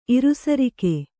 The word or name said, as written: ಇರುಸರಿಕೆ